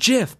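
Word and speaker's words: GIF: Put, like, we put a rule in our channel where it automatically bans people who like try to like say it's pronounced JIF. How do you even explain that? It's pronounced JIF, but they just spell the word. – They'd have to spell it out with a J. So that kind of says something right there, right? – Ooh, wow. Okay. Yeah. – If you have to type out the pronunciation, you have to use a J.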